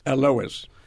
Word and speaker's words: Alois: Alois.